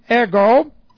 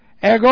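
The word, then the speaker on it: ego (ἐγώ): And I, and then we have ego, E-G, long O, ego, I myself.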